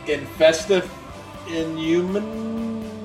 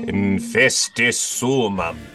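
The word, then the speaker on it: Infestissumam: Well, you already said you don't care much for their second album, and I'm going to butcher this fucking shit. Infestive Inhuman? – Infestissumum.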